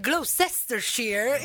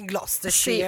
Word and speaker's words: Gloucestershire: quello che per me era il Gloucestershire, invece è il Gloucestershire.